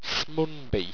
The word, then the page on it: smunby: owstwecian